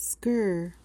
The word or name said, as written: skirr